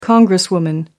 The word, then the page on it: congresswoman: American English